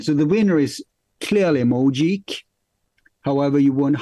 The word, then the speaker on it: Mojeek: It's spelled M-O-J-E-E-K. – No, I mean, how would you pronounce it? Sorry. – How you just said it. I remember I just told you. – Mojik.